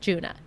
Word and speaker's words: Djuna: And I'm pretty sure in the Provincetown Players episode, I was like calling her like Juana Barnes because her name is spelled D-J-U-N-A. – Yeah. – And it just looks confusing, but it's just Juna.